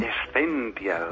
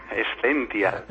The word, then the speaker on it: Essential: Essential, con S antes de Z. – A ver, otra vez. – Essential. – Essential, vale. ¿Qué es Essential? – Pues mira, Essential